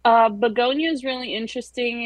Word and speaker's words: Bugonia: But another one that fits into that category, and help me on the pronunciation, is it Bugonia? Bugonia? I'm not sure how you say Bugonia. – That. Bugonia. Yeah. Yeah.